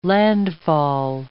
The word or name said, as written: landfall